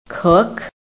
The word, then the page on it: COOK: American English